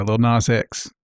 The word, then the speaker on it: Lil Nas X: Yeah, Lil Nas X.